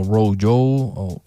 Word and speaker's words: RoJo: Sí, para Toronto, Ontario, para descubrir a Rochelle Jordan o Row Joe, se escribe rojo y se pronuncia Row Joe,